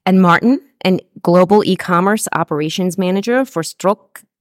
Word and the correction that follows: Stokke: Am I pronouncing it correctly? – Stokke, yeah.